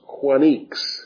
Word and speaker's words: choinix: That word is C-H-O-I-N-I-X. It's pronounced quanix.